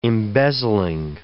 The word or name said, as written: embezzling